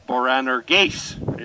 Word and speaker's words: Boanerges: to them he gave the name Boanerges is how you pronounce that. It's an Aramaic word. It's not even a Greek word.